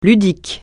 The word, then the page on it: ludique: francais